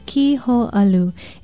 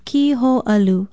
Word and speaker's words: kiho'alu: Be sure to put in the kahako or makron over the I and O and pronounce it kiho'alu.